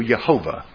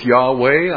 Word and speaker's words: Jehovah: The only way we know how to pronounce these is because the Masoretes gave us vowel points, and we pronounce it, I do, Jehovah, or Jehovah. I don't go with Yahweh.